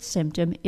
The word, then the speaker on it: symptom: The reason is syllable stress and schwa. The word symptom is a two-syllable noun. The two-syllable noun stress pattern tells us that two-syllable nouns tend to be stressed on the first syllable, and the word symptom does follow this pattern.